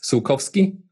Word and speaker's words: Sulkowski: It's all usually you just mispronounce the O. It's Sulkowski.